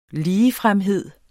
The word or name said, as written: ligefremhed